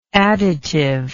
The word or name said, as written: additively